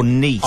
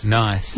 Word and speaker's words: Nice: He actually inadvertently cleared up a question that apparently we've all not been asking, and that's whether a certain brand of biscuits is pronounced nice or niece. – I say niece. – Nice. – What is it? – Well, Bruno asked Arnott's which is the correct version, and they responded on Twitter. Apparently, it's named after the French city. So, it's pronounced Nice.